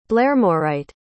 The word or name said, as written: blairmorite